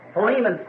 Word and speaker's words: Philemon: So Philemon, someone said back there, which I think is really the correct pronunciation of it.